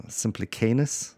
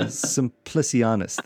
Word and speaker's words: Simplicianus: When your servant, now I think I'm saying this right, Simplicanus. – Nailed it. Done. – How would they do it? They'd go Simplicianus.